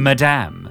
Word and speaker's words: Madame: So it's more like Madame. – Like the French, actually, isn't yes. – it? – How do you say it? Madame. – Madame. – Madame Magenta. – Have we been saying it wrong all this time? – Yes. – All right, well, it's Madame Magenta. – The emphasis is on the damn.